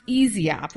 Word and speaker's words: Yseop: Well, you're a mission at EasyOp, and how's it spelled? – Y-S-E-O-P. Yes. – But it's pronounced EasyOp, which is kind of cool.